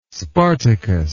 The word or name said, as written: spartacus